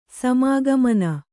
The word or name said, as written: ಸಮಾಗಮನ